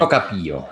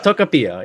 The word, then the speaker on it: Tocopilla: This film is a semi-autobiographical story of Alejandro Jodorowsky as he is raised in the town of Tocopilla. I'm probably pronouncing that totally wrong, but that's fine. – Tocopilla. – Tocopilla.